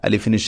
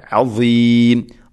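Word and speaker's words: അസീം (عظيم): പ്രത്യേകം ശ്രദ്ധിക്കേണ്ടത് അസീം അലീം അലീം അല്ല അസീം ഈ ഉച്ചാരണം. അസീം അവസാനത്ത് നീട്ടൽ മദ്ദഅറുദുൽ സുക്കൂൻ.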